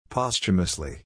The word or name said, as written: posthumously